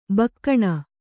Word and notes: ಬೋಗಣಿ: bōgaṇi

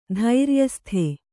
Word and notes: ಧೈರ್ಯಸ್ಥ: dhairyastha

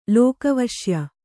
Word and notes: ಲೋಕವಶ್ಯ: lōka vaśya

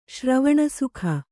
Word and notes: ಶ್ರವಣಸುಖ: Kannada

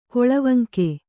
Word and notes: ಕೊಳವಂಕೆ: Kannada

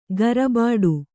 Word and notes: ಗರಬಾಡು: garabāḍu

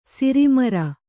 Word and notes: ಸಾರಮಾರಿ: sāra māri; Kannada

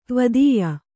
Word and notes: ದ್ವಿತೀಯ: dvitīya

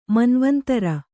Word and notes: ಮನ್ವಂತರ: manvantara

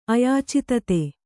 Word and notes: ಅಯಾಚಿತತೆ: Kannada

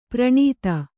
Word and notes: ಪ್ರಣೀತ: praṇīta; kannada

